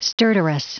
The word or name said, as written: stertorous